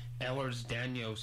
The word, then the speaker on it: Ehlers-Danlos: And one of the members of the Fibromyalgia Wellspring Foundation up here had EDS, or Ehlers-Danlos syndrome.